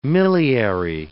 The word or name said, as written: miliary